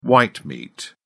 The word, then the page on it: white meat: British English